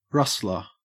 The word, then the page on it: rustler: Australian English